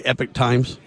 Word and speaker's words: Epoch: Yeah, so technically, a few pronunciations are correct. Epoch Times is also correct. If you're an American, Epoch Times, technically. If you're British or Canadian, Epoch, but sometimes people say Epoch anyway.